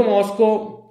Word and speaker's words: MoSCoW: Il metodo Moscow, che si pronuncia esattamente come la capitale della Russia, o se preferite come il Moscow Mule,